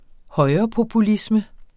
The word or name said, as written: højrepopulisme